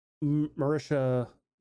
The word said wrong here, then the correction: Marissa: Marissa. There's no H.